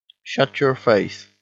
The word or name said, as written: shut your face